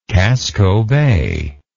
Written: casco bay

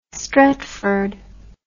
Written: Stretford

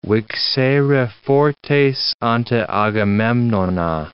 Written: vixere fortes ante Agamemnona

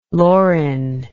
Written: lorin

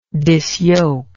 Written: disyoke

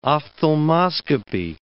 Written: ophthalmoscopy

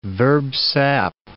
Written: verb sap

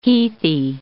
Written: heathy

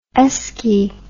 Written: esky

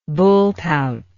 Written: bullpout

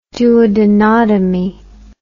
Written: duodenotomy

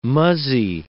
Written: muzzy

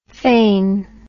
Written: phane

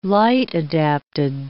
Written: light-adapted

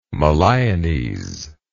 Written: Moliones

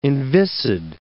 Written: inviscid

